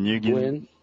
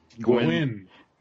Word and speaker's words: Nguyen: You know when Scott Snyder tweeted like a picture of some new woman that Dustin New, how do you say his name? Dustin Nguyen. – Gwyn. – The N is silent.